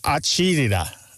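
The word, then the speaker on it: acidità: It's pronounced something like acidita, acidita.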